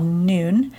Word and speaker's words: Nuun: And I like to add an electrolyte tablet the morning of the cleanse called Noon. It's spelled N-U-U-N, and it helps with flavor and also can help with hydration, and it doesn't have any sugar.